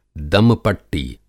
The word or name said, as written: दम-पट्टी